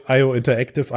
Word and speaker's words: IO Interactive: IO, IO, IO Interactive, IOI, genau.